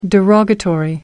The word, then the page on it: derogatory: American English